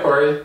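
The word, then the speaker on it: Cory: All right, Corey.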